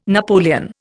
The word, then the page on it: napoleon: Indian